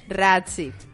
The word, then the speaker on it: Razzie: Pues yo que vengo, yo que traigo los los eh, eh Recie. Recie. – No, no, se escribe Razzie. – Razzie.